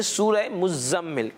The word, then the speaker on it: मुज़म्मिल: देखा गया है कि इसकी लोग इसकी प्रोनाउंसिएशन गलत करते हैं। मीम जो ज़ा पर तशदीद है इसको नहीं पढ़ते, तो इसको यूं पढ़ेंगे सूरह मुज़म्मिल।